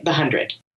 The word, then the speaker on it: The 100: That's how they say it, The 100. – Yeah, The 100. – But the actual show is, you look at it's the and then the number 100.